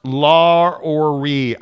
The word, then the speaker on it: Lauri: It's pronounced La-or-ri. I speak Finnish, FYI. Say it right, Gonzaga. – Wow. – It's pronounced Lauri.